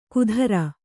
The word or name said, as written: ಕುಧ್ರ